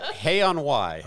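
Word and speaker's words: Hay-on-Wye: Hay on Wye.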